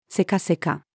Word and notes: せかせか: female

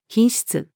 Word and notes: 品質: female

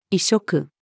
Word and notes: 異色: female

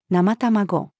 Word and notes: 生卵: female

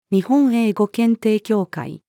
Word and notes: 日本英語検定協会: female

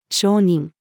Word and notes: 承認: female

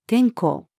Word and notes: 天候: female

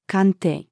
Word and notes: 鑑定: female

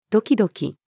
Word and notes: ドキドキ: female

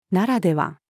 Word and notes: ならでは: female

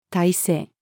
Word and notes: 体制: female

